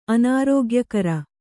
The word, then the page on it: ಅನಾರೋಗ್ಯಕರ: kannada